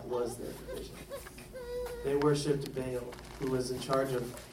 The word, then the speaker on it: Baal: I can say Baal! Or Baal is the correct pronunciation.